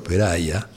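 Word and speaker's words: Perahia: Y pronuncio así su apellido, Peraya, a pesar de que es Murray y de que nació en Estados Unidos, aclarando, antes de que me llegue alguna queja eh, por mail, que es un sefaradí y que entonces su apellido es un apellido sefaradí y que se pronuncia así, no se angliciza.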